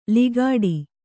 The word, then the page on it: ಲಿಗಾಡಿ: ligāḍi